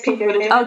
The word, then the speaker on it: Pete Buttigieg: and I might butcher his name, Pete Buttigieg? – Yeah, it's Pete Buttigieg.